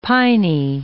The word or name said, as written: piny